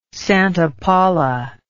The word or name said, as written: Santa Paula